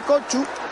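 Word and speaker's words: Kökçü: Por eso le decimos Koku, pero la pronunciación correcta debería ser Kochu.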